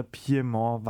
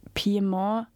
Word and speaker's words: Piemont: Ich habe mal nicht gewusst, dass man das Piemont ein weibliches Pronomen hat. Du hast gerade in der Piemont. Ich hab doch das Piemont. – Du hast dem Ganzen gerade eine französische Aussprache gegeben.